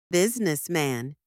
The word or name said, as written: businessman